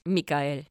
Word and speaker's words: Michael: I am curious about what's going to happen with Michael, because Michael now does not have his wings. – Mikael, because it rhymes with Samael.